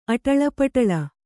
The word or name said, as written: ಅಟಳಪಟಳ